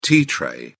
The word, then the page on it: tea tray: British English